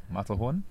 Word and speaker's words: Matterhorn: That was Tony Mafferhorn Dirty Wine. – You didn't say the name right, but okay. – Is it not Mafferhorn? – No. – Matterhorn? – Yeah.